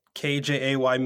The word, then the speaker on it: KJay: So I'm everywhere on the internet at KJAY Miller. And I always tell people the K is silent.